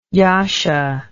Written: jascha

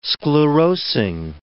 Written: sclerosing

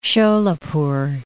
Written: Sholapur

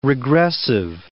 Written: regressive

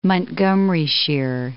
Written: Montgomeryshire

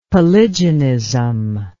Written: polygenism

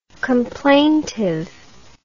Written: complaintive

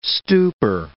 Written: stupor

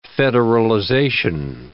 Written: federalization

